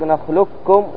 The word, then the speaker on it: نخلقكم: الخلف يعني الخلاف، الأول الإدغام المحض أي الكامل بحيث تنطق نخلقكم، وهو قول الجمهور، واختاره أبو عمرو الداني وابن الجزري والشاميون، وهو المقدم في الأداء لحفص.